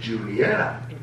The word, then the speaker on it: Junietta: It looks like Juanita, but it's Junietta.